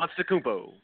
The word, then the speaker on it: Antetokounmpo: Because they have a lot more going for them than with Antetokounmpo, whatever his name is. I cannot pronounce it. – Antetokounmpo.